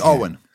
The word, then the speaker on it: Eoghan: It's Owen. – Eoghan. Damn. That's a crazy spelling. E-O-G-H-A-N.